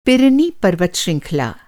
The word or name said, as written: पर्वत श्रृंखला